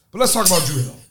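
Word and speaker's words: Dru Hill: So Drew Hill, D-R-U Hill is the name of the group, but that's how Druid Hill is pronounced in Baltimore because of, you know, the accent.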